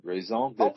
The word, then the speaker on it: raison d'être: Here's how you really pronounce the word. Raison d'être. – Oh, wait a minute. – Raison d'être. – Raison d'être. It's not the terre, it's the tread or something like that.